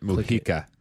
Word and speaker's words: Mujica: Okay, it's, I believe, President Mujica. It's with a J, but President Mujica donates about 90% of his monthly salary. – I would pronounce it that way.